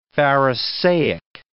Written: pharisaic

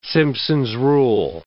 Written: Simpson's rule

